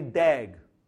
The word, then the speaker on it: DAG: A directed graph that contains no cycles is called a directed acyclic graph, which we call pronounce as a DAG.